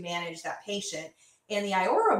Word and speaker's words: Iora: I think it's pronounced Iora. It's I-O-R-A.